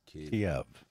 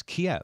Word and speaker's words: Kyiv: How do you pronounce the capital city? Is it Kyiv? – Kyiv. – It's Kyiv. Okay. – I've heard it both.